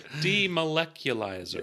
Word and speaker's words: demolecularizer: You said demolecularizer. – Yeah, I mispronounced that and I didn't want to go back because I pretend like I did it correctly. – Then your husband pointed it out.